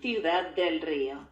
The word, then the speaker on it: Ciudad del Rio: Ciudad del Rio.